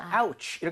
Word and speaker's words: ouch: 여기서 오우가, 오우가 아니라고요. 아우로 발음이 됩니다. 그래서 아우치 이렇게 얘기를 하면 어이쿠 아야 라고 할 수가 있는데 아하.